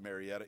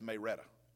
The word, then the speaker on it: Marietta: If you're from Marietta, you don't pronounce it Marietta, it's Mayretta.